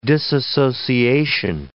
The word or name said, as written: disassociation